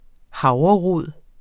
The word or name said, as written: havrerod